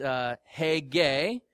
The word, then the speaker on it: Haggai: His name in Hebrew is pronounced Haggai, which means festival.